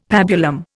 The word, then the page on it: pabulum: Indian